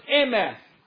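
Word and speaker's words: emeth (אמת): The word truth is formed from three Hebrew letters, and it's pronounced emeth.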